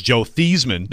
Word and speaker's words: Theismann: The actual correct pronunciation is Joe Theismann, but when he was at Notre Dame trying to go for the Heisman, he changed it to Theismann for Heisman.